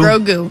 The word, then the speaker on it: Grogu: Is that how you pronounce it, Grogu? – Grogu.